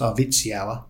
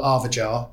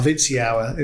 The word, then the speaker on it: Arvidsjaur: But when you fly into it, the locals tell me it's pronounced Arvidsjaur, which is the airport we fly into. Many people call it Arvajar, which I don't think is correct. But Arvidsjaur is how I was, because there was a guy I worked with at Lotus who helped design the Avora chassis, and he was Swedish.